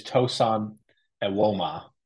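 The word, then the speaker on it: Tosan Evbuomwan: For what it's worth, it is Tosan Ewoma. – That's right. That's right. – That is the correct pronunciation.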